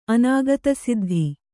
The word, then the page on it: ಅನುಕ್ತಸಿದ್ಧ: Kannada